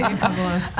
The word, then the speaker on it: Persepolis: I should say that correctly. Persepolis. The Complete Persepolis by Marjane Satrapi.